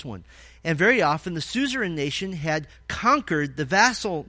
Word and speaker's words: suzerain: Suzerain, the suzerain nation, and it's spelled, if you're interested, S-U-Z-E-R-A-I-N, like Suzy Rain.